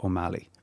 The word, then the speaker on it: Ó Meallaigh: Yeah, I think it depends on where you're from. Like, I would pronounce it... See, some people wouldn't put so much emphasis on the G in at the end, so it actually would sound more like O'Malley. – O'Malley, yeah. – But like, I don't mind either.